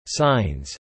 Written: signs

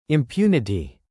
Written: impunity

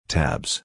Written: tabs

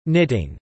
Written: knitting

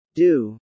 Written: due

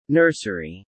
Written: nursery

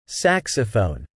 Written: saxophone

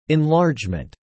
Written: enlargement

